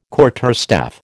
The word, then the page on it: quarterstaff: American English